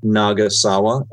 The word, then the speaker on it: Nagasawa: So my name is spelled Mako, M-A-K-O, Nagasawa, N-A-G-A-S-A-W-A.